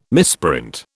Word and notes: misprint: British English

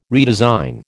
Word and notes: redesign: American English

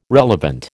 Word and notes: relevant: American English

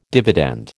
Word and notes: dividend: American English

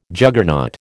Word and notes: Juggernaut: American English

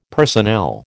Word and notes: personnel: American English

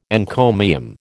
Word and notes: encomium: American English

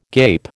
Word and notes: gape: American English

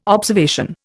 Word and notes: observation: Indian

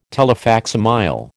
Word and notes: telefacsimile: American English

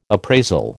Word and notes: appraisal: American English

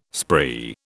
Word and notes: spree: British English

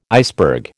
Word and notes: iceberg: American English

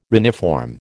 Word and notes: reniform: American English

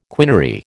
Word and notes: quinary: American English

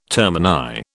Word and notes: termini: British English

